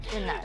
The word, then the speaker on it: Yunnan: I'm not saying that right, Yunnan, right? – Yunnan.